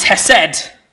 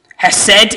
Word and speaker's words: hesed: Now, the Hebrew word for mercy is hesed. The H is much more strongly pronounced, but I'm not going to try and do that in front of you on a mic.